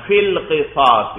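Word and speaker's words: فِي الْقِصَاصِ: تو فی القصاص ہی دیکھو, یا اور الف میں نہیں پڑھ رہا, فا کے نیچے کسرہ پڑھ کر اس کو لام کے ساتھ ملا رہا ہوں. فی القصاص ہی, تو فا 1, لام 2, قاف, صاد, الف, صاد, کتنے ہو گئے؟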